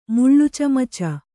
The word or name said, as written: ಮುಳ್ಳುಚಮಚ